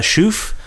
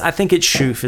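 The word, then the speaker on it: Chouffe: And I think it's Schoof is how you pronounce it. It's Belgian. – Uh-huh. Oh, it's Belgian. – From the south of Belgium.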